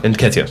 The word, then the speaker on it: Nketiah: Is it pronounced Nikita? Nikita. – Nketiah.